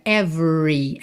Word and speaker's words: every: Every, lembra que o E do meio não é pronunciado. Então você vai do V direto para o R. R enrolado da porta com a perna esquerda. Every, every.